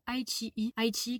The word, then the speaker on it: iQIYI: Si jamais vous voulez le voir gratuitement et de très très bonne qualité, je vous recommande de télécharger l'application ITI qui s'écrit I-Q-I-Y-I.